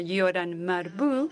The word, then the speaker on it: Yoram Marbu: Yoram Marbu, así es como se pronuncia, y me dijeron que así era como se pronunciaba, Yoram Marbu.